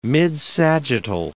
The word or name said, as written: midsagittal